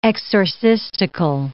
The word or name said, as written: exorcistical